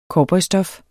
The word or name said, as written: cowboystof